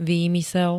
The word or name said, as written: výmysel